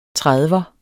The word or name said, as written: trediver